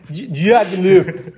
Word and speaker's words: Diaghilev: Solo dopo Stravinsky scopre in realtà una parte delle musiche, dei manoscritti che Diaghilev, io correggo la mia pronuncia, Diaghilev in Diaghilev, grazie al maestro Caragò. – No, è che chi lo sa se è giusto. – No, è Diaghilev.